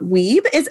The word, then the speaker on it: Wiebe: So today I have Nyla Weeb.